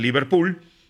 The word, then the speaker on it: Liverpool: Vamos a ver cómo le va a Federico Martínez, el hombre de Liverpool, que, de Liverpool, perdón, que ha llegado, Liverpool de Uruguay, se pronuncia diferente del equipo de Inglaterra, allá es el Liverpool, en Uruguay es el Liverpool, este, por ser más latinizado.